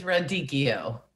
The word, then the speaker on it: radicchio: Don't they say radicchio? – Yeah, it is radicchio. You're right.